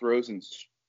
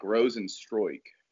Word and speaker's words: Rozenstruik: I think it's pronounced Rozenstruik. Oh shit, man! I looked it up today. – That's it. Forgot it. It's got to be Rozenstruik. I can't think any way else you would say that. – It's pronounced like Stroik or something. It's like Rozenstruik.